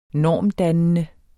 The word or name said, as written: normdannende